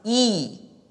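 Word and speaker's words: yi (義): So what you see on your left is the word lamb pronounced yang, and the character me pronounced wo, and the composite character righteousness pronounced yi is righteousness.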